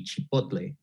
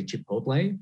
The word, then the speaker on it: chipotle: So yeah, the correct pronunciation in Spanish, if we're going to get technical, it would be chipotle. It's okay in English to say chipotle, but it's just funny to see videos of older people trying to pronounce this one.